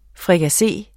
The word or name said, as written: FRIKASSE